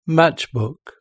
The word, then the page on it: matchbook: British English